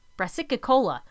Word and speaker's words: brassicicola: So it's Alternaria brassicae, B-R-A-S-I-C-A-E, and Alternaria brassicicola, that's it, brassicicola. B-R-A-S-S-I-C-I-C-O-L-A.